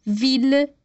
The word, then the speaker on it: ville: No entanto, para as palavras mille, ville e tranquille, a gente vai pronunciar os dois L's com L, normal.